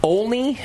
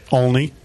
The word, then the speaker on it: Olney: All right, so today on the show, we've got Simon Olney. Am I saying the last name right, Simon? – Near enough. – Yeah. How do you say it? – Olney.